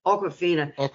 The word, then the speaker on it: Awkwafina: And although I don't see her as a comedian, but I love, is it pronounced Awkwafina? – Awkwafina.